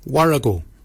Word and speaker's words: worragol: Barken is spelled B-A-G-I-N-Y. Barken. Barken is your beard. The upper mouth, we say warrigal. Warrigal. Warrigal is spelled W-O-R-R-A-G-O-L. Warrigal. Warrigal is your upper mouth. For your nose, we say mwyl. Mwyl is your nose. Mwyl is spelled M-O-O-L-Y.